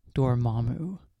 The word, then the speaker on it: Dormammu: I want you guys to say Dormammu.